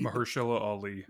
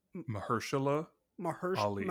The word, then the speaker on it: Mahershala Ali: Do you know how to pronounce the Mahershala Ali? – Say it again. – Mahershala Ali.